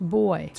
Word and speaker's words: buoy: Yeah, Emirates Team New Zealand unhooked itself from a starting buoy. – Boy. That's pronounced boy.